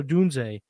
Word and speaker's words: Odunze: The next receiver, we teased it a few minutes ago, University of Washington, Rome Odozie. How do you say his name? I'm terrible names. Odunze. – Yep. – Sounds way cooler than I said it, so thank you, for that.